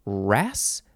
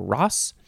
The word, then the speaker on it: wrasse: This is from the Greek kikli, which means thrush, or a kind of wrasse, W-R-A-S-S-E. I'm going to say it's pronounced wrasse or ross.